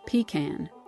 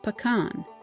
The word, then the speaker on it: pecan: Her father was from a small rural town called Americus, and he insisted it was pronounced pecan. Meanwhile, her mother, who hailed from the mighty city of Atlanta, said pecan.